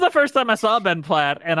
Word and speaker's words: Ben Platt: You've missed, you mispronounced Ben Platt's name. You want to take that again? – Okay, sorry. Ben Platt.